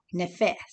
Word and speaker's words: nephesh: And the Hebrew, it's a Hebrew word for soul, is nephesh. And you spell it N-E-P-H-E-S-H. It's a Hebrew term, and it's called nephesh. Nephesh. and it means that which breathes, the breathing substance or being, soul, inner being of man.